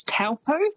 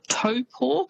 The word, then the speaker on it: Taupo: but famous spots including Taupo. So quite often you'll hear that said as Taupo. – Yes. – It's almost how it's spelt, but that Taupo is actually pronounced Taupo.